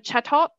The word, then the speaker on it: Chatot: I got a lot of Chatot.